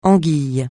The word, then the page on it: anguille: francais